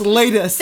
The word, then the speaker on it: Laeddis: L-A-E-D-D-I-S. If your last name is L-A-E-D-D-I-S, pronounced Latus.